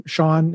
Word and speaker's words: Sean: You can just look up my name, Sean. It's spelled like Sean Connery.